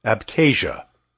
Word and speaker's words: Abkhazia: And in all the Caucasus, the area most renowned for its extraordinary number of healthy centenarians, people above the age of 100, was Abkhazia, pronounced Abkhazia.